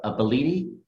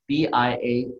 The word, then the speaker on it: Bialetti: It's called the one I was thinking of is a Bellitti. – Yes, that's I'll spell it. – Okay. – Bialetti.